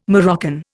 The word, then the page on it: Moroccan: Indian